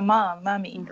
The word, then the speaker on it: mami: because that's how that's how you call the mommy, right? – Mommy. – It's spelled M-A-M-I, and I think it was important for me to make that distinction, that there are two languages at the house.